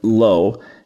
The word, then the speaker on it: lo: There is a word lo, which the Hebrew letters Lamed Aleph spell it lo, and it means no, not.